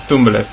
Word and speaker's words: Tumblr: Tumblr.